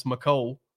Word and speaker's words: Mecole: Apparently, it's Mecole, is what he told everybody. – I've been calling him Mecole. – I know. I have to. I can't. I have a hard time. – Mecole Hardman Jr. He deserves to get it right.